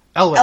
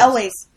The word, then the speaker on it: Elwes: The Crush is the one with Carrie Hughes. – Elwes. – Elwes.